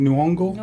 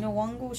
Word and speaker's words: Nyong'o: How do you say her name? – Nyong'o. – Nyong'o.